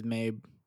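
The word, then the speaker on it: Mei: Think you think it's May? – I think I've heard it pronounced as Mabe. – May. Okay. So it's M-E-I.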